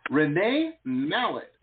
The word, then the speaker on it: Mallett: Next up, we've got Renee Mallett. And Renee, I hope I'm saying that correctly. – You are. You got it.